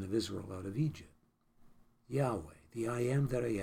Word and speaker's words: Yahweh: Well, the word Lord in the Hebrew original language is Yahweh, or some pronounce it Jehovah, but the more accurate pronunciation is Yahweh.